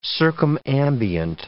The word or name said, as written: circumambient